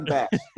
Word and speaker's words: Basch: My wrestling coach actually used to always say Basch, and that carried on for so long that I'm like, you know what? I can run with it. So people have said both Basch and Basch, and I'm good with either at this point.